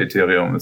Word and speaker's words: Ethereum: Also ich höre es in 90% der Zeit als Ethereum, aber Ethereum ist genauso korrekt, wie du willst.